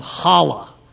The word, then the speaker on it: challah: This is a bread called challah. Can you say that? Let's really try to get it to the Jewish way. Challah. That's it. That's how you say it.